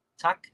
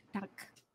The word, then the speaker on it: Tak: Tak. – Tak.